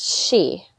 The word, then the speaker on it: sidhe: Okay, so, the she, spelled S-I-D-H-E, but it's pronounced she. I may have pronounced it incorrectly before, and I apologize about that.